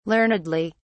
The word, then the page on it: learnedly: British English